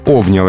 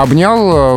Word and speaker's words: обнял: И вот здесь обнял это варианты эталонный, образцовый, литературный, угу. – а обнял э, вариант неошибочного, в словами фиксируется, но он допустимый то есть менее желательный, а лучше обнял.